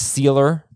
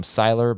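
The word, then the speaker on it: Sieler: So they have been a very good run defense with Wilkinson, and I think his last name is pronounced Sealer up front. I think I've been calling him Siler, but two defensive tackles who were great last year and good this year.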